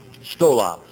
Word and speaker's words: stola: Stola. Now there are two words. One is stola and one is stole. S-T-O-L-A or S-T-O-L long A. Stola, short A, stole, long A.